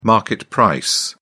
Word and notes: market price: British English

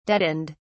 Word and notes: deadened: British English

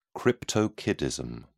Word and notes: cryptorchidism: British English

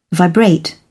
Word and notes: vibrate: British English

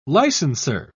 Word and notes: licenser: British English

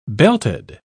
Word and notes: belted: British English

